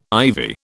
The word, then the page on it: ivy: British English